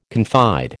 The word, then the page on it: confide: American English